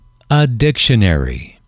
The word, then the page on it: a dictionary: male